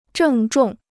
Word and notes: 郑重: zhèng zhòng

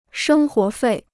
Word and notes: 生活费: shēng huó fèi

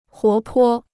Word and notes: 活泼: huó po

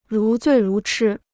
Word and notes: 如醉如痴: rú zuì rú chī

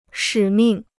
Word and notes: 使命: shǐ mìng

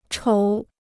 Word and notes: 瞅: chǒu

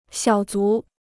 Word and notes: 小卒: xiǎo zú

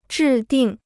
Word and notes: 制定: zhì dìng

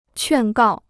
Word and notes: 劝告: quàn gào

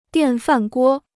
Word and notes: 电饭锅: diàn fàn guō

